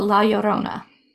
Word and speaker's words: La Llorona: I was really excited about this one for a long time because it's based around, I butcher it every time and I feel so bad, La Llorona. – Oh, La Llorona. – Yes, you say it so much better than I do.